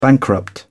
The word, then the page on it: bankrupt: British English